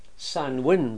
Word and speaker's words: Samhain: They were observing festivals, and there's one festival in particular which is, it's pronounced Samhain, but it's spelt something quite different. It's spelt S-A-M-H-A-I-N, Samhain.